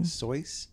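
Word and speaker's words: Seuss: I know it's actually pronounced Soyce.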